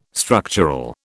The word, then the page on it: structural: British English